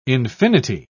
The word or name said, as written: infinity